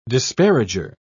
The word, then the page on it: disparager: British English